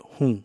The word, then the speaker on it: rum: The largest drum is called hum. – Spelled R-U-M, like the alcohol you used to make mojitos. – But pronounced hum, like, well, I can't think of anything.